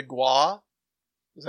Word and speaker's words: Gwaii: Then I got the Gua. Is that how would you say that? Gua? Guaii? Guaii? G-W-A-I-I? – That'd be Guai. – Guai? – Guai. – Like Hawaii. Guai.